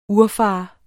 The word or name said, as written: urfader